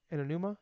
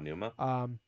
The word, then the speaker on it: Aonuma: It might have been Anuma. I can never say his name. – Yeah, Anuma. – Aonuma.